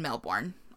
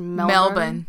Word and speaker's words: Melbourne: So, at the team trials in Seattle, she qualified to compete in the 200-meter individual event at the 1956 Summer Olympics in Melbourne, Australia. – It's pronounced Melbourne. – Melbourne, Australia. Excuse me. There's no in there.